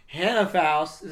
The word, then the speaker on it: Hannah Faust: All right, I am here with Zach Ames, Hannah Faust.